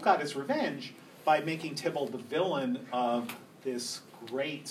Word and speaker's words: Theobald: spelled Theobald but pronounced Tybalt, who did really the first great edition of Shakespeare.